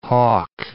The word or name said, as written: Hawke